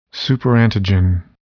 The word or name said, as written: superantigen